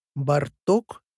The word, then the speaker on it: Барток: Барток или Бардинский. Комичное совмещение имен двух русских композиторов Александра Бородина и Игоря Стравинского с именем венгерского композитора и пианиста Беллы Бартока. Ударение на первый слог.